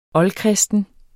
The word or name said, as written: oldkristen